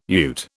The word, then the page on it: ute: British English